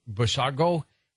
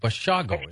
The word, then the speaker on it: Bushago: And welcome back to Coast, I'm George Norrie with our special guest tonight, Andy Bushago. Am I pronouncing your name right all night, by the way? Bushago, is it? – That's correct. It's Bushago, like Chicago.